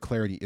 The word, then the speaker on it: Clardy: Cool, I'm with it, at Troy Clarity. The last name is spelled C-L-A-R-D-Y. At Troy Clarity is the way to go there.